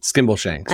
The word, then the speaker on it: Skimbleshanks: Skimbleshanks.